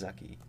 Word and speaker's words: Zaki: It's just Z-A-K-I. – Okay. – And it's actually pronounced Zucky, so ooh, it's like... It's like Lucky, but with a Z.